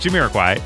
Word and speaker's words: Jamiroquai: I'm afraid I'm saying it wrong. But it's Jamiroquai.